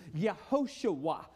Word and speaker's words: Yehoshua: You say Joshua in Hebrew, Yehoshua, and that is Yahweh or Jehovah in the Germanic pronunciation of it, is salvation.